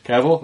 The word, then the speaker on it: Cavill: Cavill, all right.